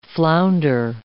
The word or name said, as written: flounder